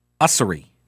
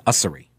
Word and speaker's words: Ussery: Do you know the name Monica Ussery? Or Ussery? I think it's Ussery. If we're being formal, I think it's Weesery. No. It's spelled U-S-S-E-R-Y, Monica Ussery.